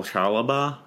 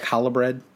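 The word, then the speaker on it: Chalobah: The other guy to keep an eye on is Nathaniel Chalaba. I don't know how to pronounce that name, but he is... – So like Hollabread? – Yeah, like Hollabread.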